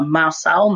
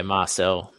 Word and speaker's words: Marçal: Um, don't rule out, and again, I'm going to butcher his name, but Mar- Marçal, Mar- Mar- yeah, Marçal nailed it, mate.